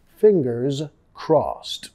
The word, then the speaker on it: fingers crossed: Fingers, sonido de Z al final, fingers crossed. Crossed he de pronunciado como una T.